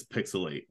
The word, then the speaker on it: PixelHate: This is Pixelate, where the H is silent and the hate is real.